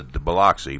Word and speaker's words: Biloxi: Yeah, they go to Gulfport, they go to Biloxi, and I've been to Biloxi, but I can't think of that other one. – I gotta teach you how to pronounce it. Biloxi. – Biloxi, okay. I got Biloxi.